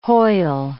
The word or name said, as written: Hoyle